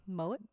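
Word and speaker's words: Moët: There's also a male apparition thought to be a family member of either the Moet. I still say it wrong. – Moet. – Moet. Thank you.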